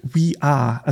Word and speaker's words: We Are: Haben ganz viele haben natürlich gefragt, ist das wer, wie wird es ausgesprochen, aber du hast es richtig erfasst. We are.